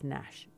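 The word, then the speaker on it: Gnash: And it's like G Nash, except he pronounced it just Nash, just so you know.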